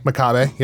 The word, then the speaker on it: Makabe: Makabe, am I pronouncing that right? – Makabe, yep.